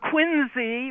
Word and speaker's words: Quincy: And Quincy, with a Z, is the proper pronunciation.